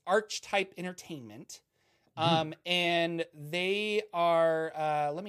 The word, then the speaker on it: Archetype: I pronounce it Archetype. – Archetype. Okay. Yeah. Again, it's like Covenant of the Ark. Ark of the Covenant.